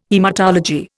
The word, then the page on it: haematology: Indian